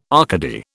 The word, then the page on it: Arcady: British English